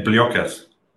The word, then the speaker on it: Pliokas: And Paulo's going to tell me how to say it with the Greek pronunciation that I can't do. – Yeah, Pliokas.